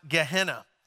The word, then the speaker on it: Gehenna: There's another way they described the afterlife, and it was a word that's pronounced Gehenna.